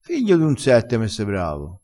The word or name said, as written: figlio d un sette